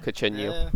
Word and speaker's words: cochineal: and thus they need cochineal. Is that how it's pronounced? – I was got told cochineal. – Cochineal.